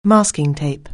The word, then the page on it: masking tape: anglais